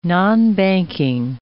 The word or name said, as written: nonbanking